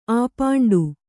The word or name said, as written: ಆಪಾಂಡು